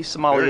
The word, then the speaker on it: sommelier: A tea sommelier, am I saying that right? – Yes. – A tea sommelier. – Very good.